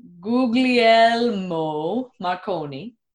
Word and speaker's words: Guglielmo Marconi: So in England, there's, this is going to be our first correction, my pronunciation of this. Guglielmo Marconi.